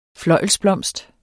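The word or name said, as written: FLØJLSBLOMST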